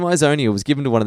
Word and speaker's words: Ixonia: Izonia, it's an X, didn't you say? – Yeah, but it's an X that can be like xylophone, pronounced as a Z.